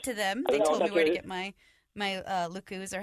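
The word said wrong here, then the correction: lekku: or however you pronounce that. – I think it's pronounced Leku.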